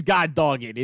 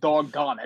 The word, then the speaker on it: doggone: Producer of Shine Night Sports, what is God dog it? Is that an expression that one uses? Or is it the other way around? – It's definitely a mispronunciation of doggone it.